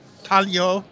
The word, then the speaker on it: Taglio: One more time, it's Taglio. – Taglio Pizza in Mineola, Long Island. – And that's spelled T-A-G-L-I-O. – T-A-G-L-I-O.